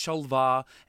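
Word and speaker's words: Shol'va: And I said Shova, and I'm like, I know it's Sholvar, and I pronounced it wrong.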